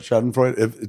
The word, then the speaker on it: Schadenfreude: Maybe that's part of the Schadenfreude? How do you say Schadenfreude. – It? Schadenfreude.